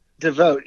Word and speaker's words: Divote: And you've got something called D-I-V-O-T-E. Make sure I say this right. Is it Devote? – Devote, yeah.